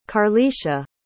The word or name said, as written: karlisha